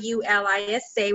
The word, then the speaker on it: Yulisa: Yeah, so I have my personal um, Facebook, which is Anna and A N A, and my middle name Y U L I S A, which is pronounced Ulisa Robles, and um, and then you, I have an Instagram, which is Life's Journey to Excellence.